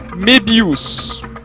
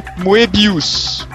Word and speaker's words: Moebius: Eu vi ele pronunciando em documentários Mebius, bem a francesa. Ele o próprio Moebius falando Mebius, então.